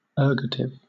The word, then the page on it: ergative: British English